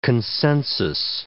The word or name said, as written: consensus